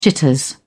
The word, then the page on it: jitters: British English